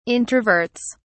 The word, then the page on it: introverts: British English